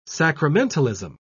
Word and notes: sacramentalism: British English

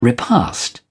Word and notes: repast: British English

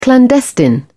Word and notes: clandestine: British English

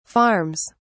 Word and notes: farms: British English

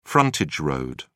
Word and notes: frontage road: British English

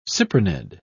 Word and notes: cyprinid: English; British English

